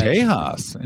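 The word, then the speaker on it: Texas: Tejas, interesting. – Oh, yeah. I pronounce the X as an H.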